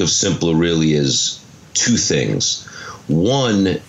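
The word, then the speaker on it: Symplur: It's S-Y-M-P-L-U-R. It's pronounced Simpler.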